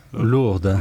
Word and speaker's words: Lourdes: Lourde.